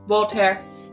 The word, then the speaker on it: Voltaire: Voltaire.